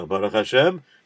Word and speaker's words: Baruch Hashem: Yeah, Baruch Hashem, you learned to say that right.